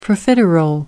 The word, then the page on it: profiterole: American English